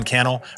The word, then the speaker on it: Cannell: My name is Sean Cannell, rhymes with YouTube channel, and I can't wait to connect with you in a future episode.